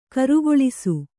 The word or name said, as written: ಕಾರಗಲಿಸು